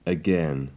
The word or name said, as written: again